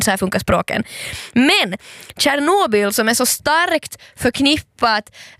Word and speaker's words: Tjernobyl: Men man ska börja tala om liksom kärnkraftsolyckan i Tjernobyl.